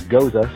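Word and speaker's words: Gose: I've heard it pronounced a myriad of ways, but to my understanding, the correct pronunciation is Goza, so therefore Goza, the Gozerian.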